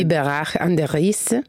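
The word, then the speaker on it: Biberach an der Riß: Biberach an der Riss.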